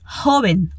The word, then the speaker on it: joven: Em espanhol, usamos a palavra joven, ok? Mais uma vogal para focar na pronúncia. O O é com a boca bem fechadinha, como em bolo.